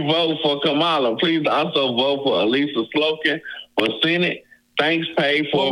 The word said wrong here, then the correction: Slotkin: Make sure you got to say it right. It's Slotkin. You got to say Slotkin.